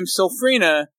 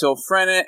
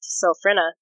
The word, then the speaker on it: Sylphrena: I know some people probably want to pronounce her name Silfrina, but it's pronounced Silfrena, and I'll tell you why. Because I know where her name comes from. It's a portmanteau of the word sylph, which is a wind sprite, and the word phrenic, which is a word that means pertaining to the diaphragm.